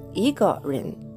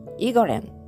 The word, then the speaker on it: 一个人: で、この場合は、イガレン、イガレンというふうに発音します。